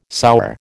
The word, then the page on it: sour: American English